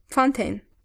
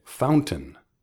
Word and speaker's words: fountain: Fuente, mm, fountain, fountain. – That's right, fountain. Otra vez, la terminación rima con ten.